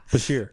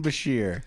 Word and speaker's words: Bashir: Basher. Bashir. – Julian Bashir. – Terrorist. – Dr. Julian Bashir.